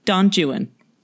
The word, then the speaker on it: Don Juan: Yeah, it's spelled Don Juan, but it is supposed to be pronounced Don Juan.